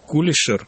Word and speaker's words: Кулишер: Дело в том, что Евгений Михайлович Кулишер, ну, я вот так привык делать ударение, хотя петербуржцы меня поправляют и говорят, действительно надо Кулишер, но вот очень известный на Западе ученый, эксперт в области миграции.